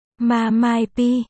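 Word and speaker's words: Ma Mai P (妈卖批): Ma Mai P. Phát âm là MA. Mai. P.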